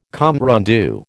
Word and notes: compte rendu: American English

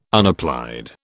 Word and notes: unapplied: British English

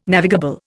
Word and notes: navigable: Indian

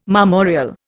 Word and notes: marmoreal: Indian

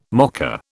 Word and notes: mocker: British English

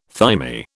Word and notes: thymi: British English

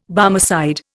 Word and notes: Barmecide: Indian

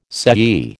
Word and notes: sei: American English

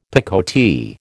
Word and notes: picotee: American English

